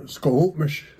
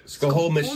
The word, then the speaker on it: Squamish: How do you say? – Squamish. – Squamish. – Yeah.